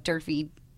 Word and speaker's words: Durfee: And as my LinkedIn as well is Kelly, KELLEY, Durfee.